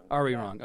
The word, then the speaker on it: Arirang: Arirang.